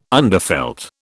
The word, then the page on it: underfelt: British English